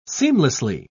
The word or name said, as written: seamlessly